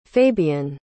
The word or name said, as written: fabian